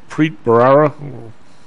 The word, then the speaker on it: Preet Bharara: Preet Bharara? – Yep.